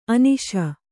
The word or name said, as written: ಅನಸು